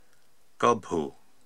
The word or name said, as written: Gobhu